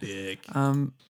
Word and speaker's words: Björk: Or Björk, how you say it. – Björk.